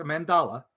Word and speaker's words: mandala: So, making a sun catcher is big on this day, or just making a solstice mandala, not the Mandela effect, a mandala. Sorry, I mispronounced that.